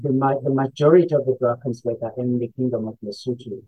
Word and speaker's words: Drakensberg: Um, I will help you how to pronounce the mountains where I started in South Africa. It is called the Drakensberg, the Drakensberg mountain range.